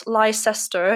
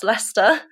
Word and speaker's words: Leicester: There was a school in Leicester, which is not Leicester, it is pronounced Leicester.